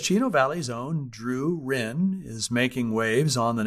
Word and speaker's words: Ryn: A local singer, Drew Ryn, I'm going to pronounce that R-Y-N. She spells her last name. I'm going to pronounce it Ryn. Could be Rhine.